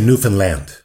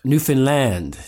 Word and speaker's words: Newfoundland: Say the word understand. – Understand. – Now say Newfoundland. – Newfoundland. Newfoundland. Okay, I hope I don't botch this. I apologize in advance if I do. – If Newfoundland, understand, same thing.